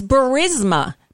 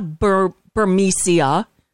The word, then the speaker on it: Burisma: It's Burisma. Burisma. It's not Burmesia.